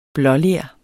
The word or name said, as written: blåler